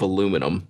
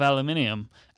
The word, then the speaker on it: aluminium: It's just like a big block of aluminium. Aluminium, it, how, you're saying it wrong, by the way.